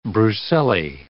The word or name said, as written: brucellae